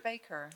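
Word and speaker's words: Bakker: Is it Backer or Baker? – It's Baker.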